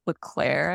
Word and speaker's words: Leclerc: I really prefer, I think it's actually pronounced Leclerc, I recently learned, but Leclerc's and Macomer's, which is the kind of loom that I learned on.